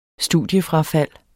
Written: studiefrafald

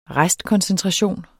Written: restkoncentration

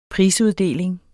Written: prisuddeling

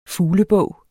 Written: fuglebog

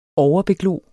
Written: overbeglo

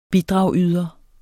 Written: BIDRAGSYDER

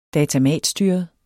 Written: datamatstyret

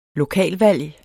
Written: LOKALVALG

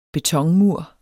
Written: betonmur

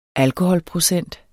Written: alkoholprocent

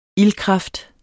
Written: ildkraft

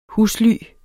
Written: husly

